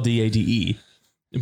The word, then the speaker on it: Dade: In my it's pronounced it's, it's spelled D A D E. But oh, that's Dade.